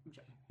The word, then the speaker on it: Joep: J-O-E-P. – Yeah, Jep.